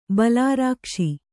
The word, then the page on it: ಬಲರಾಕ್ಷಿ: bala rākṣi